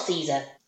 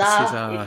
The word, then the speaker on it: Cesar: And it's not Caesar. I'm just going to point that out here. I'm not saying it wrong. He's Cuban. He's not Italian. He's not. It's Spanish. It's Cesar. Cesar.